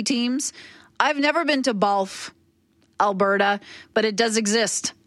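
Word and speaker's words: Bawlf: Isn't there a town called Balf? I think it might be spelled B-A-W-L-F. I could be mistaken, but I think there's a town in Alberta called Balf, Alberta. And that rhymes with golf.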